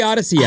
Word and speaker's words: Audacy: A-U-D-A-C-Y. It's spelled funky. It's like a made-up word. We made it up. It's audacious. It's audio. It's awesome. It's like a trip. – Go on. – A-U-D-A-C-Y.